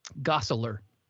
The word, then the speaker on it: Gosselaar: Gosselaar.